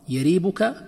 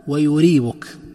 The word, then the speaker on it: يريبك: يريبك تنطق بالفتح والضم، يريبك ويريبك، والفتح أفصح وأشهر، ويصح الضم.